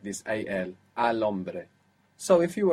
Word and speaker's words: hombre: For example, the word for man was, begins with a silent H. – Hombre.